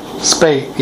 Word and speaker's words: Spate: I sure like the plays that, how do you say his name, Spate? – Spate, yeah. Wilton Spate. – That's how you pronounce it.